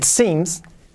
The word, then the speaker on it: seems: Sim, S-E-E-M. E e Fijaos, aquí tenemos dos s y, son, y se pronuncian ahí. It seems, la primera es it, la i como una e.